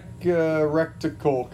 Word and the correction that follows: reticle: It's a reticle, okay, guys?